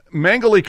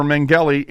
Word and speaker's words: Mengele: Now, Mengele comes up, or Mengele, and you say it both ways. I've watched documentaries where they say his name both ways.